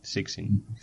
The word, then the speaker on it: Zixin: Creo que se pronuncia, porque lo estuve mirando, la C es como una S. Sixin. Sí, es Sixin, eh, o sea, para nosotros es complicado hacerlo.